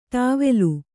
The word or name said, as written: ಟವಲ್